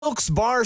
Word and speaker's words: Wilkes-Barre: Wilkes Barre Scranton's 97.9X. – Now, I had heard that it was supposed to be pronounced Wilkes-Barre. Wilkes-Barre Scranton's 97.9X. The only way to pronounce Wilkes-Barre.